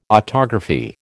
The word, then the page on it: autography: American English